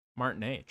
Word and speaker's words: Martinet: Mario's longtime voice actor, Charles, how do you pronounce his last name? – Martinet.